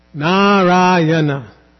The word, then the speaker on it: Narayana: So it's, in this case, Narayana. Not long like that. Four syllables, and you stress, don't give any extra stress unless, again, there is a diacritic mark.